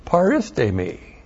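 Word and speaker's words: Paristemi (παρίστημι): Paristemi. P-A-R-I-S-T, long E-M-I. Paristemi. This is the word.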